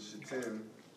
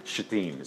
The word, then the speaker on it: Shittim: While Israel was staying in Shittim... – Thank you for pronouncing that correctly, by the way. Guy in my residency class didn't. It was a bit awkward. – Oh. – Shittim is perfect, yes.